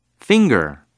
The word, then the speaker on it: finger: 손가락은 영어로 finger. – finger. finger 이렇게 발음해요. 처음에 F, F 사운드가 있어서 F, F 이렇게 약간 토끼처럼 만들어서 바람을 내보내주세요. F. finger 이렇게 발음하면 되고요.